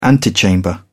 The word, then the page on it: antechamber: British English